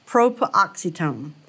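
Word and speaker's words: proparoxytone: It's P-R-O-P-A-R-O-X-Y-T-O-N-E. Propoxetone.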